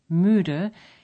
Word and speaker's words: müde: To pronounce a U umlaut, as in müde, you simply say a long E and round your lips again.